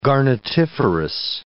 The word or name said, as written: garnetiferous